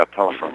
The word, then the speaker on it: Polifrone: Well, this Dominic Polifrone, is that the way you say his name, last name? – Yeah, Polifrone.